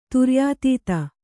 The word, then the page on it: ತುರ್ಯಾತೀತ: turyātīta